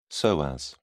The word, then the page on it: psoas: British English